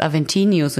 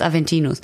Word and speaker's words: Aventinus: Aventinius. – Krieg von Fuck. dir? Ach nee, ich dachte gerade, der heißt Aventinius und ich habe das falsch gesagt. Der heißt Aventinus.